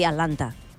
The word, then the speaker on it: Atlanta: hem de dir Atlanta. Una altra cosa és que efectivament com que vull dir la T la pronuncien tan poc que acaben no pronunciant-la,